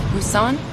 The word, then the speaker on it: Busan: Busan? Is this the correct pronunciation? – That's right.